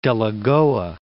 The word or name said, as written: Delagoa Bay